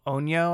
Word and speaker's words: Onryo: Yeah, it's called Onyo, I think, is how they pronounce it. It's spelled O-N-R-Y-O, and it's a vengeful spirit.